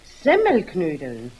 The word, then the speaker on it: Semmelknödeln: Jetzt hast du nämlich gerade gesagt Semmelnknödeln. – Ist recht, Semmelnknödeln. – Nein. Richtig muss es eigentlich heißen Semmelknödeln. Die Semmel muss man dabei betonen.